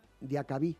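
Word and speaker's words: Diakhaby: Por ejemplo, uno de los casos más recientes del fútbol español es el de Cala y Diakabi. ¿Se dice así? – Sí. – Mira, lo he dicho bien.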